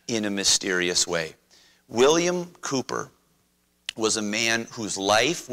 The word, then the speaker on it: Cowper: In English, in American English, we might say Cowper, because that's what it looks like. William Cooper, in the British pronunciation, wrote "There is a fountain filled with blood."